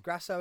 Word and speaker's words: Grasso: God, so Barber and Grass, how'd you say his name? – Grasso are going at it.